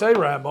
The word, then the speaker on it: Arab: We're getting a yes, and we're getting that I pronounced it correctly. – It is. Oh, it's Arab, all right.